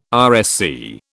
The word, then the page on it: RSC: British English